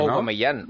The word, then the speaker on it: Aubameyang: Aubameyang, oh, pero es O. ¡Oh! – Aubameyang. – Auba, pero él ha dicho que le llamen Auba. Sí. – Hace unos días dijimos que se pronunciaba con la O.